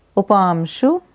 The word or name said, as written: Upamshu